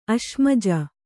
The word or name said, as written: ಅಶ್ಮಜ